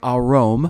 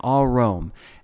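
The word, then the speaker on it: arom (ערום): And it means, it's pronounced, sorry, arom. Now, there's two different ways of spelling it in the Hebrew. Ayin resh mem or ayin resh vav mem, but they're both pronounced the same, all Rom.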